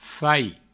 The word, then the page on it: fais: Provençal